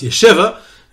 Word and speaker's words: yeshiva: Of course, I'm pronouncing this yeshiva incorrectly, because in the Midwest, in Detroit, it's pronounced yeshiva. And since I started off with talking about the correct pronunciation of cities, so if I'm talking about the schools in Detroit, it's definitely as a yeshiva, not a yeshiva.